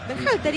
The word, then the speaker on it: Berhalter: ¿Cómo es el apellido? – ¿Cómo? – Dígalo usted. – Berhalter. – ¿Y qué dije yo? – No, impecable.